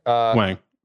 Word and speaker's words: Wang: Wong or Wang. That's another pronunciation question. But Wang, for sure.